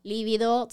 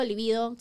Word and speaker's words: libido: tu líbido sea mayor o menor, ¿no? Y creo que aquí sí es importante que definamos, ¿no? Como Líbido, libido, se puede pronunciar de, ambas maneras, eh, se refiere mucho al inicio específico de la conducta sexual.